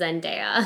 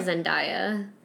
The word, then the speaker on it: Zendaya: I just pronounced her name wrong. Her name is Zendaya. – Zendaya. – Call her Zendaya.